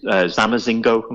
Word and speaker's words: zamazingo: Dingsbums in German, hubbledypup in Dutch, zamazingo, am I saying that right, in Turkish? – Yeah.